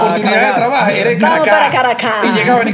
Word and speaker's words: Caracas: Caracas no lleva acento. Sí, yo no lleva acento, pero, la, sílaba tónica es en, la segunda.